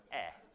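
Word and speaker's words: eh (η): It's actually eta, and it's pronounced eh, but previously was a h, he, he.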